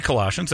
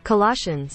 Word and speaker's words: Colossians: Colossians. I've never heard that one before. – Let's hear Google announce it. – It'll say Colossians.